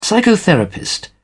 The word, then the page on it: psychotherapist: British English